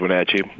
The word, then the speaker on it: Wenatchee: Sorry I mispronounced. Is it Wenatchee? – It is Wenatchee.